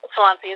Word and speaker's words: Swansea: It's Swansea? How do you pronounce that? – Mm-hmm. Yep, Swansea. It's about 6 minutes off the interstate, right south of Columbia, the capital.